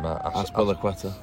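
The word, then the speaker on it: Azpilicueta: Aspilicueta.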